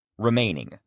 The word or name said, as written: remaining